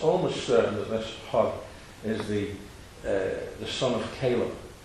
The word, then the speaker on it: Hur: It says also that Hur, I'm trying to pronounce it, I normally say her, but I'm trying to give you the R's that you have in Scotland. Hur.